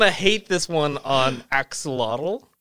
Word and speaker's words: Axolotl: It's pronounced Ashlot. – Neutral. I kind of... – The L is silent at the end. – Okay. – And the X is a sh sound.